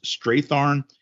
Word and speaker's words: Strathairn: David, I believe it's pronounced Strathairn. Rob Rifkin.